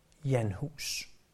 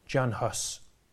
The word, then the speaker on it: Jan Hus: Eller som man siger det på engelsk, om end han ikke var englænder, John Huss. Men Jan Hus er den nok mest korrekte udtale, eftersom det er sådan, man stadig udtaler hans navn, der hvor han kom fra.